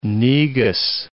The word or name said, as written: negus